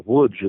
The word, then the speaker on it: Łódź: It was largely centered in what was the first Polish industrial town, and that was Lodz, or Łódź, as it's pronounced in Polish, where there was a tremendous film school and a tremendous effort at artistic development.